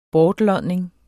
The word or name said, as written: bortlodning